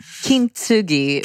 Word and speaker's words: kintsugi: So the first thing I want to talk about is kintsugi wellness. Tell me how to say that, your new book. – Kintsugi wellness. You said it right.